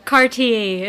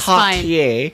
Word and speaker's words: Cartier: Cartier. – Is Cartier.